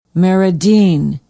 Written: meridean

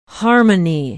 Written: harmony

